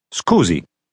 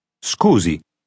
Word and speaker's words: scusi: Scusi. – You should repeat after the speaker gives the correct response, trying to make your pronunciation sound like his. – Scusi.